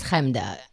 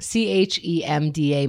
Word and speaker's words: Chemda: Hi, my name is Chemda.